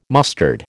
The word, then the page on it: mustard: American English